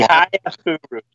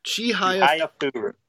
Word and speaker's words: Chihayafuru: Chihayarafuru, I never know if I'm saying this right. – Chihayafuru. Chihayafuru.